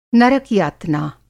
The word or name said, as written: नरक-यातना